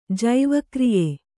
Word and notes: ಜೈವಕ್ರಿಯೆ: Kannada

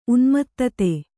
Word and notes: ಉನ್ಮತ್ತತೆ: unmattate

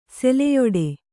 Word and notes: ಸೆಲೆಯಾಡು: seleyāḍu

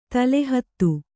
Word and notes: ತಳಹತ್ತ: kannada; taḷa hatta

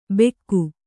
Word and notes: ಬೆಕ್ಕು: Kannada

